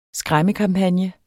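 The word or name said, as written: SKRÆMMEKAMPAGNE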